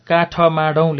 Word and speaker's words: काठमाडौँ: काठ माडौँ लेख्छौँ हामीले लेख्दाखेरि तर बोल्दा काठमाडौँ भन्छौँ भनेपछि ठ छोडियो तिन अक्षरको भयो